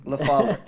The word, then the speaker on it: LaFollette: LaFollette.